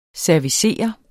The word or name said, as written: servicere